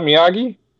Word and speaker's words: Miyagi: M-I-Y-A-G-I? – Miyagi. – Is that Miyagi? – Yeah, Miyagi.